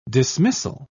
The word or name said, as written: dismissal